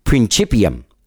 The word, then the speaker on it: Principium: Now, this is the follow-up album to their 2021 EP, Principium, which we talk about the pronunciation of that, and I finally got it right.